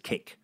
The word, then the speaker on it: KAKE: And K-A-K-E News is simply pronounced as cake.